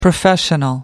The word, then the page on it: professional: American English